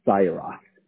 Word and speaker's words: Xayyarath: Last name, Syrah. X-A-Y-Y-A-R-A-T-H. Hence, Mr. X. – Ah, I've never seen a last name this unique. This is cool. How do you pronounce that again? – It's pronounced Syrah.